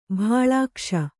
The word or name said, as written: ಭಾಳಾಕ್ಷ